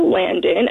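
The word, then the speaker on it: Landen: It's T-H-E-A-L-A-N-D-E-N. The E is the crucial one. Everyone wants to give me a no. There's no O in my name.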